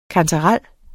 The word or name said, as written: kantarel